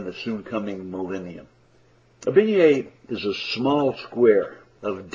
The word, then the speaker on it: beignet: Let me introduce you to another Louisiana French word, beignet. It's spelled B-E-I-G-N-E-T. B-E-I-G-N-E-T.